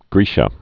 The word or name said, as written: Magna Graecia